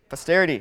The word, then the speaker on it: posterity: Now therefore swear to me by God that you will not deal falsely with me, with my offspring, or with my, how do you pronounce, what? Patastery? I don't know how to pronounce that. Posterity. Thank you.